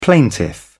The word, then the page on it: plaintiff: British English